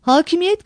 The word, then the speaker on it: Hakimiyet: Hakimiyet kelimesindeki K incedir. Dolayısıyla önündeki ünlü de iyidir. Hakimiyet şeklinde telaffuz edilmemelidir. U, Ü değişmesi.